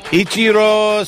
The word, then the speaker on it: Ichiro: Ichiro Suzuki! – Ichiro! Yes. – Did I say it right? – Yeah. – Okay. How old's Ichiro? – Gosh.